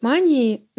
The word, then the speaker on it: манией: По сути, гипомания — это состояние, сходное с манией, но менее тяжелое в своих проявлениях. То есть зачастую называют легкая степень мании. В профессиональных кругах манию принято называть манией, то есть с ударением на первый слог.